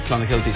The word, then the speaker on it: Clonakilty: Irish tea bags are Clonakilty sausages from Cork. – Clonakilty, thank you.